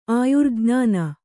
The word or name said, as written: ಆಯುರ್ಜ್ಞಾನ